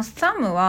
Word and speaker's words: thumb: で、このサムは THUMB って書くんですけど、最後の B は発音しません。